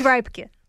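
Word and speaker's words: Reubke: Certainly a curious one for me, Julius Rebecca, am I pronouncing that correctly? – No. It's no. – Not quite close. It's Reibke.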